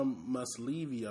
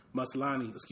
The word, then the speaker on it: Maslany: Actress named Tatiana Maslany, I believe that's a pronunciation of her name. Maslany.